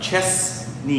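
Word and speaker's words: Chesnee: Chesney. You got to say it right. – It's like Clemson and Clemson. Clemson. – It's like Chestnut Chesney.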